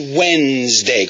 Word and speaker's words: Wednesday: No es Wednesday, porque en inglés no se pronuncia todas las letras de las palabras, pero decimos Wednesday, como cuando, con ese. Wednesday.